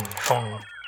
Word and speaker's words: Phaune: I mean, phone radio. It's spelled P-H-A-U-N-E. But it's pronounced phone.